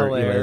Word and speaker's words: Helaire: Yeah, it's Hilaire. – Is it silent H? – I think it is a little bit, yes. – Eclair.